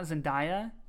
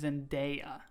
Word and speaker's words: Zendaya: It is not Zendaya, apparently. It is Zendaya. – Oh. – So, Zendaya, I know you're listening.